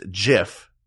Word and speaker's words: GIF: is because the inventor of the GIF, whose name is Steve Wilhite, I believe, he said it's pronounced JIF, not GIF.